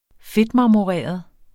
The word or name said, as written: fedtmarmoreret